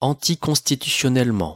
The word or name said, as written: anticonstitutionnellement